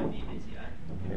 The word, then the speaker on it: Aivazi: Ivazi. – How do you spell it? – Ivazi. – A I V A Z I.